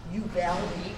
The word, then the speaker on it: Uvalde: But the nearest big town is Uvalde.